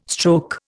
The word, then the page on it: stroke: Indian